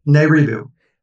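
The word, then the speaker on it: Nibiru: So we could say you, your suggestion is that planet, how do you pronounce it again? Nairibu. Nairibu. hmm Okay.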